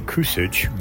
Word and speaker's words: Kusic: My name, it's pronounced the last name Kusic, Greg Kusic, and I'm a recently retired professor of English or British literature from the University of Notre Dame in America near Chicago.